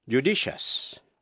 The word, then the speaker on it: judicious: Nếu trước đuôi IOUS có âm T, C hay X, thí dụ, judicious, judicious, J-U-D-I-C-I-O-U-S, judicious là sáng suốt.